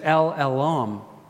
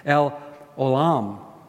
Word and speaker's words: El Olam: Then there's El Olam. I said it wrong. El Olam.